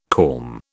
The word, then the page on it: corm: British English